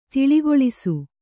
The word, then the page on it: ತಿಳಿಗೊಳಿಸು: tiḷigoḷisu